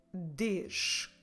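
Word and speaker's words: dês: Não des.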